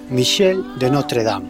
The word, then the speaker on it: Michel de Notre Dame: Mira, aprende, se pronuncia así. Michel de Notre Dame.